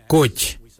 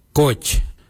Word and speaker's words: goodj: Karli is spelled G-I-I-L-Y-I, karli. For the traditional stone axe, we say koj, koj. Koj is spelled G-O-O-D-J, koj.